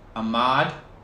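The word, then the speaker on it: Ahmaud: I believe his name is pronounced Ahmad.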